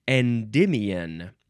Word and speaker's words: Endymion: Next is endymion. Capital E-N-D-Y-M-I-O-N.